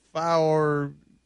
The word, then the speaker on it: Farxiga: F-A-R-X-I-G-A, Faurziga.